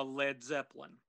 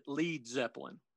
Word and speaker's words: Led Zeppelin: It would be like a Led Zeppelin. – Huh. – Page made one slight spelling change, that being to drop the A from lead so that nobody would mistakenly pronounce it Lead Zeppelin.